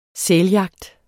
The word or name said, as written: sæljagt